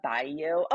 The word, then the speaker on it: Bilyeu: Glennon Doyle, Abby Wambach, I like um, Lisa Bailey's um, podcast. I'm probably not even saying it right. Um, it's Woman of Impact with Lisa Bilyeu.